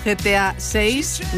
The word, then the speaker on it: GTA 6: Y hoy tenemos una cita para los amantes de los videojuegos, GTA 6. No sé si se dice así, Manuel, me corriges. – Así se dice.